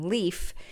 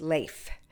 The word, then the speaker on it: Leif: Now, I know most Americans pronounce his name Leif, but when I was speaking in Minneapolis, or I think it was Duluth, actually, years ago, I was talking about Leif Erikson, and a woman came up to me and said, that's our son's name, and it's pronounced Leif.